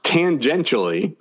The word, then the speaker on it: tangentially: Well, there's no S. It's tangentially.